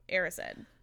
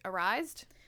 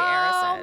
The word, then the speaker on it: Erised: So the Mirror of Erised. – Thank you for clarifying to me how that's pronounced, because I definitely always pronounce it Arised. Knew that was incorrect the whole time.